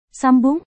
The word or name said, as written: sambung